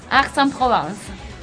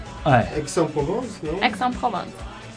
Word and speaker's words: Aix-en-Provence: Aix-en-Provence. – Aix-en-Provence.